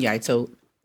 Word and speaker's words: Yaito: It is Carlton Yaito, pronounced Y-A-I-T-O.